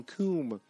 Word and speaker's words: cum: Oh, a girl touches you until you coom. He goes, what? I don't think that's how it's pronounced. No, that's it. C-U-M, coom.